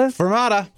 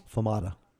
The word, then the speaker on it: fermata: Fermata. – Fermata. – Barata? Spell it for me. Spell this term. – F-E-R-M-A-T-A.